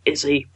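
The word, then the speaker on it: Iszi: I thought, Izzy, brilliant, got that. But it's spelt weird. It's I-S-Z-I, because I'm nerdy.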